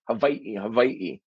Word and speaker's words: Hawaii: Yeah, that that you feel your teeth against that bottom lip too. Yeah, Hawaii.